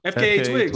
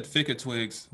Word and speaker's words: FKA Twigs: I haven't heard about FKA Twigs. Twigs. – Oh, is that? Oh, is that how you say it? I thought it was Fick. I always said Ficka Twigs, but I guess that makes sense.